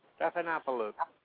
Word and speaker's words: Stephanopoulos: Stephanopoulos.